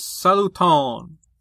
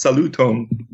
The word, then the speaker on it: saluton: It's Esperanto, and it's supposed to be pronounced saluton. – It's not saluton, French.